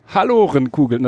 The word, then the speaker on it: Hallorenkugeln: Hallorenkugeln, das ist sogar richtig. Ich hatte gedacht, die spricht man Hallorenkugeln aus. – Ja, das klingt schon eher nach Halle an der Saale. – Ja, aber ist wohl die korrekte, ich habe bei der Ines noch mal nachgefragt, gestern Abend Hallorenkugeln.